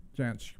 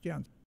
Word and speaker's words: Jansch: Janch. Yeah, that sounds about right. J-A-N-S-C-H, Janch.